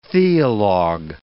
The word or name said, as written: theolog